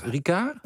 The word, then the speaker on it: Ricard: Het Formule 1 Circus is namelijk neergestreken op het Paul Richard, of Richard, hoe spreek je dat uit? – Ricard.